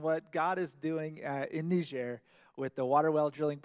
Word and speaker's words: Niger: John has helped organize short-term trips and missions in New York City, as well as in Poland and Niger and other places in the world. And John actually taught me, I've been saying it wrong for years. It's not Niger, it's Niger.